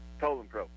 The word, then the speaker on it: Poulan Pro: Poulan Pro.